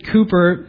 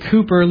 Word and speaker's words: Cowper: Now, William Cooper, which is spelled like it looks like Cowper, William Cooper lived in the 1700s.